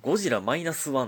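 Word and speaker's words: ゴジラ-1.0: あのゴジラマイナス 1 って読むんですねあれマイナス 1.0 と書いてえー、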